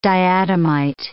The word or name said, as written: diatomite